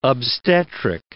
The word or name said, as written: obstetric